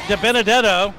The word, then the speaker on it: DiBenedetto: We're joined now by Matt DiBenedetto. I wanted to get that right out there because I said it right, didn't I, Matt? – Nailed it.